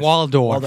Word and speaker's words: Waldorf: But I was like Statler and Wardorf are just... – Waldorf. – That's, I always say it wrong.